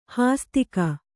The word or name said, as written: ಹಾಸ್ತಿಕ